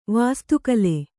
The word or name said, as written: ವಾಸ್ತುಕಲೆ